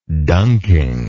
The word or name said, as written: dunking